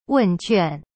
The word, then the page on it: 问卷: wen juan